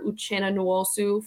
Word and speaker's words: Uchenna Nwosu: On defense, it was linebacker Uchenna Nwosu from Seattle.